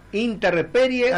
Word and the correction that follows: intemperie: Ah, intemperie.